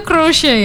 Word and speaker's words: crochet: Tulisannya kan crochet. Tapi bacanya crochet. Ya, itu bacanya crochet. Iya, crochet. Jadi crochet itu merenda.